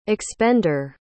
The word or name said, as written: expender